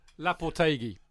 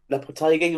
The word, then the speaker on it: Lopetegui: Was it Lopetegui? Lopetegui? – Lopetegui. – Lopetegui, right.